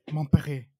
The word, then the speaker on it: Montpellier: Montpellier.